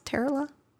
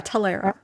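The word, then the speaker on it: Telara: Is that how you pronounce Terala? – Talera.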